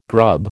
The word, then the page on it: grub: American English